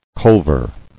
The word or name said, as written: Culver